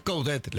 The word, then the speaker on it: Coudet: Coudet. – Coudet, se pronuncia la T. – Coudet. – Por eso, se pronuncia la T.